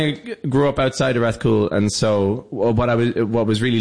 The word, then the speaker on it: Rathcoole: Rathcoole.